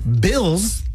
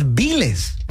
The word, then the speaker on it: bills: You're trying to say bills, like the bills that you gotta pay, pero dices biles. And that is incorrect as well.